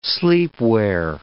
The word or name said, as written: sleepwear